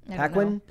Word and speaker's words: Paquin: How do you pronounce her last name? – Paquin.